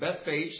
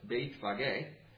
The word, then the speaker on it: Bethphage: And they came to a place, and to us it looks like Bethphage, so that's the way I'm going to say it. I think it's actually pronounced Bethphage.